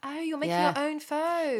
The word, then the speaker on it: pho: Yeah, I've made a pho this evening. Oh, I know, lovely. I just also pronounced that wrong. Sorry about my terrible pronunciation. It's meant to be pho, isn't it? – Pho.